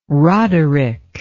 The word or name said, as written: roderick